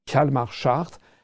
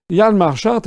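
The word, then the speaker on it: Hjalmar Schacht: Ça s'écrit H-J-A-L-M-A-R, c'est son prénom, et Schart, c'est S-C-H-A-C-H-T. Hjalmar Schart. Hjalmar Schart a été justement le gouverneur de la Reichsbank.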